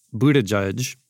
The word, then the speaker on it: Buttigieg: But because Mayor Pete Buttigieg, if I said that right.